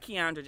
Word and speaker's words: Kiaundra: All of it is at Keandra Jackson.